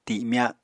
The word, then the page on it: timya: Apatani